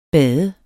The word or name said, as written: bade